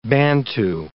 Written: Bantu

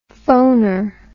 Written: phoner

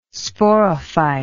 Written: sporophyte

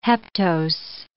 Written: heptose

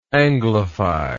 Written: anglification

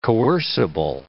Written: coercible

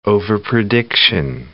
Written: overprediction

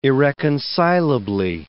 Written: irreconcilably

